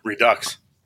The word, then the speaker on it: Redux: is that Redux or Redo? How do we say that word? – Redux. I think it's Redux.